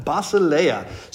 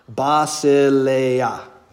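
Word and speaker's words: Basileia (βασιλεία): Basileia. So notice there's actually four syllables there. Basileia. Good. But the emphasis is on the third syllable because that's where the accent mark, right?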